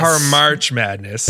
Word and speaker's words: Par'March Madness: Welcome back to Parmak Madness. – Did I say it wrong? I said it wrong. Welcome back to Parmak Madness. – Parmarch Madness.